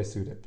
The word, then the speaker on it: Sudip: Shudip Khan would be the Bengali way of saying it. – Shudip. – But I go by Sudip.